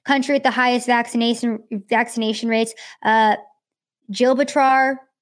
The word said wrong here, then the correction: Gibraltar: I want to pronounce words the way that they're they're written, but Gibraltar, Gibraltar, which was a UK territory, they had very high vaccination rates.